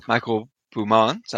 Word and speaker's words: Michel Beaumains: So, hello, I will be reprising my role as Michael Beaumont, I think is how we decided, or I decided to pronounce his name.